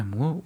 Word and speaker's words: Moore: Wie ja, also wenn wem äh, Alan Moore oder Moore, eigentlich Moore ausgesprochen,